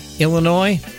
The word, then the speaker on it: Illinois: By the way, Illinois, the S is silent.